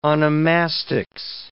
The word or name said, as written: onomastics